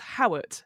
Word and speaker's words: Howat: It's just Howat, H-O-W-A-T.